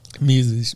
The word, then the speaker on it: Mises: Mises.